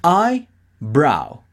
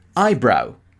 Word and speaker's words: eyebrow: ¿En inglés cómo se pronuncia? Eyebrow. Eyebrow. Yo te lo pronuncio separado para que escuches bien el sonido, pero recuerda que es junto, ¿de acuerdo? Es eyebrow.